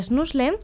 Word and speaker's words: Schnuffeln: Por ejemplo, el Schnuffeln, ¿qué es esto? – Sí, bueno, la pronunciación igual más correcta podría ser Schnuffeln.